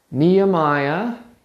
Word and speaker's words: Nehemiah: Starts with knee-high, like the pop. Nehemiah.